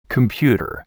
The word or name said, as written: computer